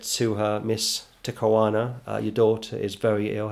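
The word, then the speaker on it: Te Kanawa: Takawana, yeah.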